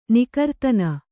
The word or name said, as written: ನಿಕರ್ತನ